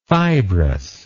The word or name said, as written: fibrousness